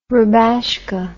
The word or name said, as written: rubashka